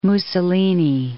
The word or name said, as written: Mussolini